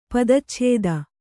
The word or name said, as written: ಪದಚ್ಛೇದ